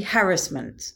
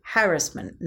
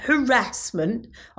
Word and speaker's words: harassment: It's actually harassment. That is the correct way of pronouncing it. Harassment, not harassment.